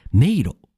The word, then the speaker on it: 迷路: 迷路、メイズみたい。迷路、迷路、迷路。発音は迷路多分書くと、めいろなんですね。でも発音すると多分、迷路、めえになるんですよね。